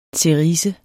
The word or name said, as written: CERISE